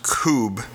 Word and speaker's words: Kubb: It's called Koob. It's spelled K-U-B-B.